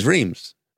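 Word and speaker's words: Reims: But this is Reams. It's easy. – So it's much easier to pronounce.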